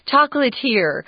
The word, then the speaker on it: chocolatier: This is a French word, just in case you've never seen it before. I really didn't know how to say it at first. Chocolatier is also how it's pronounced, chocolatier.